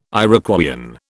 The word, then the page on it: Iroquoian: British English